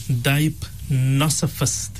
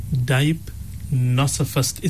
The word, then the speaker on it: deipnosophist: So the word that you were looking for, Molana, is a dipnosophist. Dipnosophist. It's spelled D-E-I-P-N-O-S-O-P-H-I-S-T.